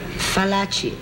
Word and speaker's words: Fallaci: Fallaci, F like flower. Not V like Venice.